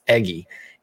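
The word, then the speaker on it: Eguy: It's Eggy. It looks like E-Guy, but it's Eggy Rosario.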